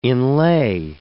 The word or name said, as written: inlay